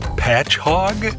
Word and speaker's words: Patchogue: The name of this city is Patchogue. – Patch Hog? – That is correct.